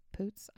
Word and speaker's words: Poots: So he's like, I'm gonna propose to my girlfriend, played by Imogene Poots. Poots? I don't know how to say it. – I think it's double. I'd say Poots.